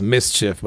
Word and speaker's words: MSCHF: But to go along with that, he teams up with a New York company, and it's pronounced Mischief, but it's MSCHF for those of you who want to look it up on Google.